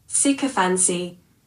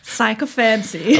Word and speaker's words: sycophancy: Hast du es rausgefunden, wie es richtig ausgesprochen wird? – Achtung. Sycophancy. Gut, wissen wir mehr, also, wissen wir Bescheid. – Ich lag falsch. – Sycophancy.